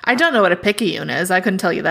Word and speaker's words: Picayune: Picayune, yeah. – Picayune? Is that how that's pronounced? – Yep, I don't know what it is.